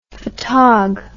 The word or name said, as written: fotog